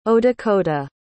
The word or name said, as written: odakota